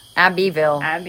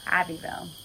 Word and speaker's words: Abbeville: Abbeville. – Sorry, I said that wrong. Where are we? – Abbeville.